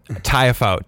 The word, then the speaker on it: Tiafoe: Tiafoe. Tiafoe. – Okay. – It's pretty much how it looks.